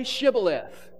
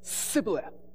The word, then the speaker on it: Shibboleth: Say Shibboleth. That's the word for stream. Say Shibboleth. And he says, Sibboleth. Because he can't pronounce his H's.